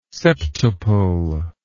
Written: septuple

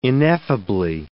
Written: ineffably

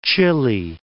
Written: Chile

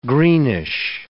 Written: greenish

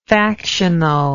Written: factionalist

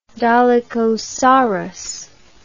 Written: dolichosaurus

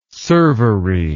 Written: servery

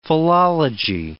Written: philology

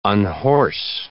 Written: unhorse

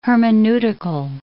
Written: hermeneutical

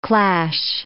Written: clash

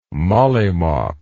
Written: mollymawk

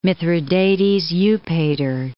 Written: Mithradates VI Eupator

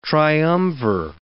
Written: triumvir

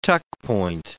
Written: tuck-point

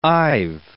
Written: I've

